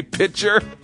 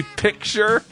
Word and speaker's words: picture: I say pitcher. But it's supposed to be picture.